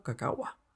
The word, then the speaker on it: cacahuá: el nombre se pronunciaba como cacahuá.